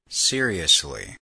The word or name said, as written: seriously